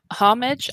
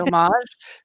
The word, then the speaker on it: homage: Both pronunciations are correct. You can say homage. I've never pronounced it that way, so it always is clunky come out of my mouth. But I've always said homage. So there we go. They're both right.